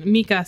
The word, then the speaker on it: Micah: The name is Micah. Like, if somebody's name is Micah, it's spelled exactly the same way. And his is just pronounced Mika for some reason.